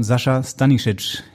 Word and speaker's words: Sascha Stanišić: Herzlich willkommen, Sascha Stanišić. Ich hoffe, das habe ich richtig ausgesprochen. – Ja, wunderbar.